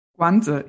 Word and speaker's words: Guanzi: You have a fascinating chapter reviewing some ancient, 28th century old literature, Guanzi, is that the proper pronunciation? – Guanzi, yeah.